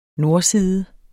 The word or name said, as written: nordside